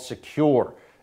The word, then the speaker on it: Sekur: That's why you need a new privacy and security outfit called Secure. It's spelled S-E-K-U-R.